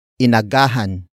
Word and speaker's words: inagahan: Word for word it's inagahan ko ang paggising.